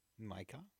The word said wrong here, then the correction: Marieke: No, Marieke.